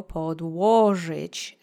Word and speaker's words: podłożyć: Now that you have that fresh on your tongue, you go podłożyć. Accent is on the second syllable.